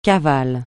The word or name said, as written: cavale